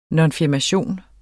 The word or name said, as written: nonfirmation